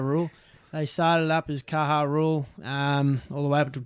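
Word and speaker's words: Caja Rural: I really want to say Cage of Rule, but I know it's Kaha Rule, dash Seguros RGA.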